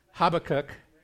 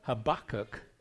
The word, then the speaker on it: Habakkuk: Because one of them is Habakkuk, right? And then there's this one where the emphasis is on the second syllable, Habakkuk. So that's the way I'm going to say it, if I remember.